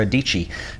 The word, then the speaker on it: Dietschy: And I want to talk about my friend Sara Dietschy. She says it rhymes with peachy, but more importantly, Sara is a very talented photographer and filmmaker who lives in Nashville, Tennessee.